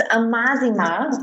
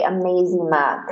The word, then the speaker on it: Amazima: But a lot of people say Amazima because it kind of looks like the beginning of the word amazing, I think. – Okay, well, I'm just a quirky wheel because I was pronouncing it Amazima. And so I wasn't doing it the right way. – You were closer than most. You just had like the emphasis off a little bit.